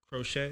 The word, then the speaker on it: crochet: Is it called crochet? Is that how, how they say it? Yes.